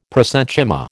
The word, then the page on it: prosenchyma: American English